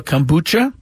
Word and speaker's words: Kombucha: Be there for weekend promotions like the Padres House Band presented by Nova Kombucha. I guess that's how you pronounce it. – Yes, you got it right.